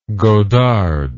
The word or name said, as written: godard